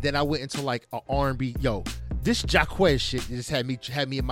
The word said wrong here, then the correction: Jacquees: So Jacquees. You keep saying his name wrong. – His name Jacquees, son.